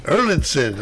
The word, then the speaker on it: Erlandson: Erlandson.